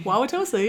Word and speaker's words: Wauwatosa: I went to high school in Wauwatosa. – Hey, I'm from there, kind of. – A suburb of Milwaukee. – Yeah. – I'm so glad I said that correctly. – Wauwatosa, yeah, you nailed it.